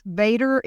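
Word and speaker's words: VATRE: Vader is voter-approved tax rate election, pronounced Vader, just like Darth Vader.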